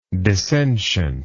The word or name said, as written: dissentiently